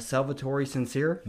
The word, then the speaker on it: Salvatore Sincere: So basically this guy, Salvatore Sincere, mm-hmm. did I say that right? Mm-hmm.